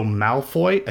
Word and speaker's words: Malfoy: They will be joined in the transfer by Poppy Miller as Ginny Potter, Sam Clemmett as Albus Potter, Alex Price as Draco Malfoy.